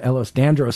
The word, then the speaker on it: Ehlers-Danlos: Last hour, we were talking about Ehlers-Danlos syndrome.